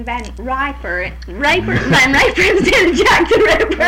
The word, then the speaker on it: Van Riper: It's just as plain as life. Van Riper, R-I-P-E-R. But as soon as I pronounce it, why, to everyone else around here, it's always Van Riper.